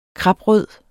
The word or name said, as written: kraprød